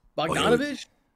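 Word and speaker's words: Bogdanović: They got, I don't even know how to say this guy's name. Bogdanovich? – Okay. You got it. You actually said it right. You got it. I'm pretty sure you said that right.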